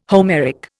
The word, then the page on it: Homeric: Indian